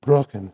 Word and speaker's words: Brocken: The Brocken is the Brocken, excuse me, not mispronouncing my German.